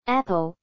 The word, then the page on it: apple: American English